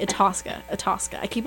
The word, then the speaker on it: Itasca: Itasca. I always want to say, like, Ithaca.